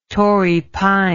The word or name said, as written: Torrey pine